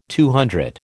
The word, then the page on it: CC: American English